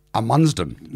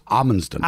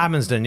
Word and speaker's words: Amundsen: Well, I should point out, I was taught to pronounce his name Amundsen when I was at school, but it's Amundsen. – Amundsen, yes.